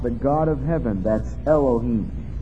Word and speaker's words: Elohim: It's pronounced Elohim.